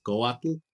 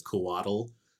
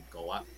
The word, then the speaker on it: Coatl: That TL sound tends to throw people off, but it's okay if you pronounce it as Coatl, with like almost like you're saying waddle. That's fine, but if you wanted it to be more accurate, it'd be Coatl.